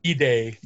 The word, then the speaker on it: bidet: Bidet, depending on how people want to pronounce it.